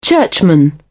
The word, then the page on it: churchman: British English